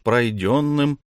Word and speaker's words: пройденным: Дорога от их будки упрется сначала в стеклянный борт ресторана «Дети солнца», ныне несуществующий, а потом уж пройденным, с ударением на втором слоге, никто пути пройденного у нас не отберет, путем доберемся до исторических колонн старого корпуса.